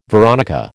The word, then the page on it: veronica: American English